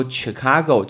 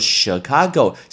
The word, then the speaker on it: Chicago: 因为芝加哥很多人会说 Chicago，这是错的，叫做 Chicago，是 sh sh sh 的发音，所以叫 Chicago。